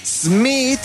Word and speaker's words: Schmidt: Smith, que se escribe S-C-H-M-I-D-T. Natalie Smith.